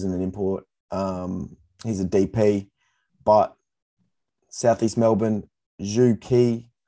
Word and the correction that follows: Zhou Qi: I've heard his name pronounced a lot of different ways. I'm not sure if that's a correct pronunciation. – Let me tell you what I found out. – What did you find out? – It's Zhou Qi.